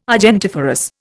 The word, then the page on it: argentiferous: Indian